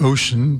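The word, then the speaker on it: O'cean: The track is called Emergence, and it's the first half of an album called Ocean, that's spelled like ocean, but with an apostrophe, so it's O apostrophe C-E-A-N. Ocean.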